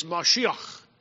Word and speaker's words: Mashiach: but it's Mashiach. It's a word that sounds kind of like Messiah. We pronounce it Messiah in English because we talk out of the front of our mouth. The Hebrews talk out of their throat.